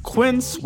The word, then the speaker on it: Quince: Today Explained support today comes from Quince, which rhymes with since but is spelt with a Q-U.